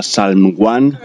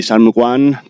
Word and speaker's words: Psalm One: Una MC de la ciudad de Chicago llamada Salm One. P-S-A-L-M-O-N-E. Para quien quiera obviar esta pronunciación defectuosa.